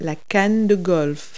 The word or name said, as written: golf club